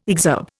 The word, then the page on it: exurb: Indian